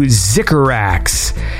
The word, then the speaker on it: Zycorax: This is, I guess, shout-out to Zycorax, which I believe that's how that's pronounced, Z-Y-C-O-R-A-X.